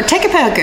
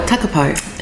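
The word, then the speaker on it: Takapau: I'm a Takapo girl. – Yeah, yeah. – So there you go. Or Takapo, as I should say. – Yeah, yeah. – Correct pronunciation. My apologies.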